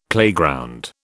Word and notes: playground: British English

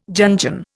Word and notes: Jungian: Indian

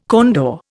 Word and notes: condor: Indian

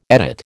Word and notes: edit: American English

